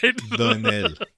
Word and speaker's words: Doinel: Doinal, we called him. You Doinal. Said Doinal last week, last episode, and in my head I was like, ah, fuck, that's so not. – Yeah, yeah, I thought pronounced. That. It was in my head as well. I thought that. – A French guy named Doinal. That can't be right. – Doinel. Antoine Doinel.